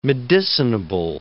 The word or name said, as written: medicinable